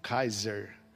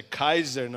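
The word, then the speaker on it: Kayser: Alemão, bairro de alemão, Kaiser. A pronúncia é Kaiser, não é Kaiser como vocês falam.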